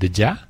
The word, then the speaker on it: Deja: Su nombre es Deja, se escribe Deja, así como suena.